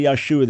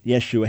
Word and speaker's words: Yeshua: Some people say Yahshua. There's no such person. That's a mispronunciation. His Hebrew name would have been Yeshua, not Yahshua, but Yeshua.